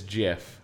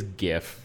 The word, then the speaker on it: GIF: and I understand that the guy who invented the format says that it is GIF but he is also wrong. – Yeah. It is GIF.